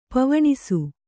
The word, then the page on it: ಪವನಾಶ: pavanāśa